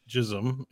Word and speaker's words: jism: Hindi word is pronounced jism in formal speech. Yeah, so the word's been around for a while. J-I-S-M? J-I-S-M.